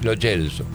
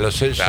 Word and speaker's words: Lo Celso: Si es italiano el apellido, si el origen del apellido es italiano, es Lo Celso. – Sí, más allá de que... – Por más que la familia le diga Lo Celso, claro, está mal pronunciado.